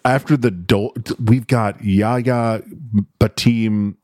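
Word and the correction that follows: Abdul-Mateen: How do you say his name? Fuck. – Who? – Hang on. – Oh, yeah, get it right. – Yeah, Abdul Mateen.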